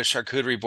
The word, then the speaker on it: charcuterie: And then this charcuterie, I was like, I don't even know what a charcuterie board is.